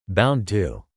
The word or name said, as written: bound to